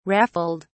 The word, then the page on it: raffled: British English